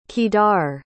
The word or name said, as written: Kedar